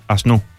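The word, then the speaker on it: Aznou: La sensación, bueno, lo primero, Asnou, el nombre, ¿cómo lo pronunciamos? – Asnou, según dijo él en una entrevista, Asnou.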